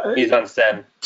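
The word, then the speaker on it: mise-en-scène: mise-en-scene, is that how you say it? – Mise-en-scene.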